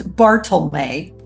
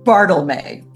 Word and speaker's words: Bartelme: In the West Loop, in an area bounded by Monroe, Adams, Peoria, and Sagamon Streets, is Bartlemé Park. If you're wondering how that is spelled, B-A-R-T-E-L-M-E, Bartlemé.